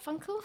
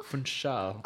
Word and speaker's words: Funchal: Am I saying that right, Funchal? – Funchal, I believe.